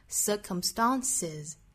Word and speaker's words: circumstances: 音结束的它如果要变复数的话直接加的话就会读成 circumstances 相当于两个辅音连缀在一起了这个时候其实是为了发音方便哈，我们在两个 s 之间去加一个元音 circumstances 那加了元音之后我们知道元音属于是浊音哈，清清浊浊的规律那后面的这个 s 的发音就会读成 s 所以说这个词变复数应该读成 circumstances